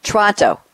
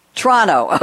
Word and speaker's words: Toronto: I had a guest on one of my radio shows yesterday from Toronto, and he pronounced it Toronto. He got rid of that first O. Is that the official pronunciation, Mike, Toronto? – The first O is kind of gone, and the last T is kind of gone, so it's Toronto.